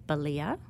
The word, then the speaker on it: Balia: So there's one written by a former clerk called A.J. Balia, which rhymes with Scalia.